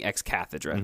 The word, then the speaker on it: ex cathedra: He's perfect sometimes when he speaks ex cathedra, which is in Peter's chair.